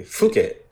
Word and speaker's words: Phuket: Phuket.